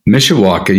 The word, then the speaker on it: Mishawaka: Mishawaka. You got it right. You got it right. That's Mishawaka.